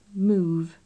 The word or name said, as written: move